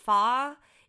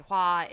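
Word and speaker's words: Hua (花): The Fa is the Cantonese pronunciation of Mulan's family name. And Hua is the correct Mandarin pronunciation.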